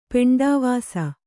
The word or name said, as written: ಪೆಂಡವಾಸ